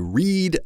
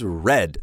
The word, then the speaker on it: read: So the present tense is read, the past tense and the past participle is read, same as the color red.